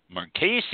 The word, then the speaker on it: Marchesi: Marchesi, it's with a hard C.